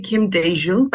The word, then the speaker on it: Kim Dae-jung: So I would say Kim Dae-jung,